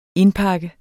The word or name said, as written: indpakke